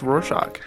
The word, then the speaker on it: Rorschach: It is supposed to be pronounced Rorschach.